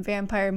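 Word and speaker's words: Vampyr: So if it's with a Y, is it pronounced vampire? – Vampire.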